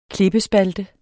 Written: klippespalte

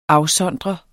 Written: AFSONDRENDE

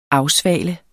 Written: afsvale

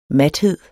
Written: mathed

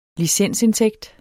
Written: licensindtægt

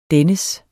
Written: dennes

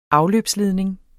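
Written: afløbsledning

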